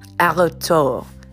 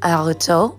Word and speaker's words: Artaud: No, his name is Antonin Artaud. So Antonin, I think, is how it's spelled. Antonin. Inon. And I'll look that up. I'll put the proper spelling in the show notes. Artaud. I know the spelling of his last name. Artaud. A-R-T-A-U-D.